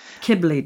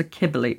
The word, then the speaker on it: Cybele: Cybele, did you say? I don't want to mispronounce the name of a goddess who has people castrate themselves. So Cybele, right, okay.